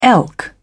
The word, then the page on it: elk: British English